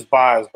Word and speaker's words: Baz: Yeah, I've I've seen Boz twice, and it is Boz, by the way, for people that are it's B A Z, but it's pronounced Boz, like Wizard of Boz.